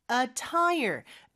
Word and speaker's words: attire: 보통 우리말로는 그렇게 얘기를 안 하니까 이렇게 권장합니다라고 했는데 business casual attire. attire가 복장이라는 뜻입니다. 그래서 우리 자동차에 다는 타이어 있잖아요. 그거 하나랑 발음은 똑같아요.